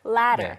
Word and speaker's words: latter: Latter. – 네, 제가 앞에 사다리하고 발음이 굉장히 비슷하다고 했는데 미국식 발음으로는 사실은 똑같죠.